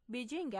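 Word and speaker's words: بیجینگ (北京): شهر تاریخی پکن که تلفظ محلی آن بیجینگ است، در شرق چین قرار دارد و از مراکز بزرگ سیاسی، اقتصادی و فرهنگی چین محسوب می شود.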